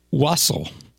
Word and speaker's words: wassail: Wassail. I guess it's pronounced wassail. According to this, what I'm reading here, it's spelled W-A-S-S-A-I-L. Like, it looks like it would be said wassail.